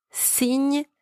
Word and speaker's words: signe: The letter Y is pronounced E, and the letters G and N together are pronounced Ny, and the final E is silent. Word number two, signe, spelled S-I-G-N-E, means sign.